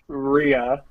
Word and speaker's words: Rhea: Yeah, it's Rhea, not Rhea.